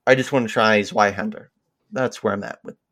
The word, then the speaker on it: Zweihänder: The Zweihänder. Is that how you pronounce it? – Zweihänder, which is a two-hander in German.